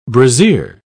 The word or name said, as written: brassiere